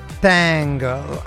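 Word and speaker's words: Tangk: Mamma mia che bel video peraltro il eh, leader Joe Talbot che si diverte tantissimo Tang è con la K che si pronuncia con una S sibilata quasi eh, una G soffiata con una S insieme un riferimento onomatopeico al modo in cui la band descrive il suono delle chitarre gli Idols che arrivano da Bristol eh, dove nasceva il eh, trip hop tanto tempo fa, che meraviglia, un video davvero pazzesco.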